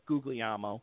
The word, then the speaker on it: Guglielmo: It's a hard name to pronounce, but it's Guglielmo.